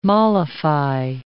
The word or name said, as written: mollify